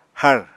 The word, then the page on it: hár: apatani